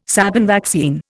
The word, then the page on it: Sabin vaccine: Indian